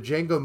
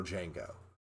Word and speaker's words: Django: The D is silent, yeah. – Yeah. That, the guy he's spelling his name to is the original Django.